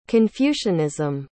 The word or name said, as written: confucianism